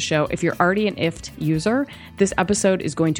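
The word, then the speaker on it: IFTTT: It's called IFT. Rhymes with gift, but really it's an acronym for if this, then that.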